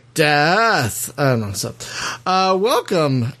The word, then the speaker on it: Daath: Did I say that right? Daath? D-A-A-T-H? – You said it all correct.